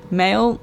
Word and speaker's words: Mhaol: So this is Pursuit by Mail, which is spelt M-H-A-O-L, because that is an Irish band.